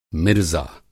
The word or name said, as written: मिरज़ा